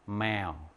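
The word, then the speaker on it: mèo: 자, 그리고 성조 한번 들어봐. 선생님 다시 발음해 볼 테니까. 매어. 매어. 뭘까요? 이것 중에서. 육성조 중에서 뭘까? 이거야. 매어.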